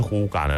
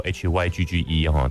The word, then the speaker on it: hygge: 好，那什么是呼嘎呢？它其实写作 HYGGE，它不叫 Hygge，它在丹麦那边念作呼嘎。